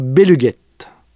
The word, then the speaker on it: Béluguetta: le groupe Béluguette. Ça s'écrit Béluguetta.